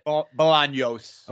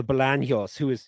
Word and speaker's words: Bolaños: Bolaños, is that how you say it? – Bolaños. – Oh, Bolaños.